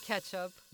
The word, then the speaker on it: catsup: Now, before we go further, I couldn't help but notice that on this largest bottle of ketchup, it's spelled C-A-T-S-U-P. So my question to you is, how would you pronounce C-A-T-S-U-P? – Ketchup. – You pronounce it ketchup. Okay. Yeah. I looked online and I found at least 3 different pronunciations for that word. I went to those little professional videos and one would say ketchup, one would say catsup, and one was like a combo of the two, a hybrid, if you will.